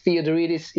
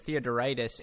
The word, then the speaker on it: Theodoridis: And it's not like Gruskin, you know, that's a pretty easy one, but Theodoridis, it's spelled exactly how it sounds.